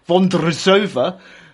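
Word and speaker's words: Vondroušová: Marketa Bondrusova beat Ons Jabeur 6-4, 6-4. I will say that again because I was quite impressed with that. Marketa Vondrousova beat Anze Jabeur 6-4, 6-4.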